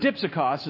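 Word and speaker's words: dipsikos (δίψυχος): Now, if you can think of Dixie cups, you've probably got it. But dipsikos is how it's pronounced.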